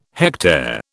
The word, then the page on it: hectare: British English